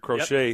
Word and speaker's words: Crochet: Garrett Crochet, that's how you say it? Garrett Crochet, yep.